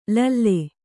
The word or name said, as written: ಲಲ್ಲೆ